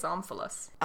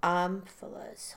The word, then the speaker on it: omphalos: But I think it's Omphalos. – Omphalos. – Omphalos. – Yeah. – Well, of course you knew. – I didn't know the word. I just looked at how to pronounce it. – All right. It's called Omphalos.